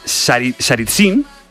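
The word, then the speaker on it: Tsaritsyn: Por ejemplo, Stalin convirtió, a ver si lo digo bien, Saritsyn... – Bien.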